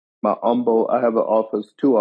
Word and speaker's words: Humble: One of them is in Humble, Texas. For those non-Texans, you might pronounce it Humble because that's how it's spelled. but in Texas, it's pronounced humble.